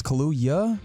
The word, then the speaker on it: Kaluuya: Because you said Kahlua. I think you got to get the Y in there. Right? It's Kaluuya. – That's the way I, I believe it it's pronounced. Kaluuya.